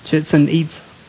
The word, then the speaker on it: Chichen Itza: And it's called, all right, I'm going to butcher the name on it. It's Chichen Itza. Pretty close? Okay. But our guide told us we can call it chicken pizza because it's so hard to pronounce it.